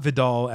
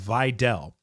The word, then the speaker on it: Vidal: Okay, we've all been saying it wrong, apparently. It is not Vidal as in Sassoon. It is Vidal.